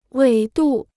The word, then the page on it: 纬度: wěi dù